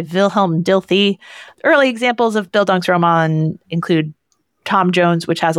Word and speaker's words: Bildungsroman: I, I, I might be mispronouncing it, but I think I know the word. It's like Bildungsroman. – Yeah, Bildungsroman is is correct.